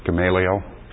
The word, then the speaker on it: Gamaliel: Gamaliel? – Yeah, the most learned.